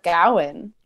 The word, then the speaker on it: Gawain: I think we should go with Gawain. – All right. – In deference to director David Lowery's choice.